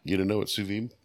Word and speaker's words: sous vide: Once I saw what it was, I was like, oh, now I know how to pronounce that. It's sous vide, by the way.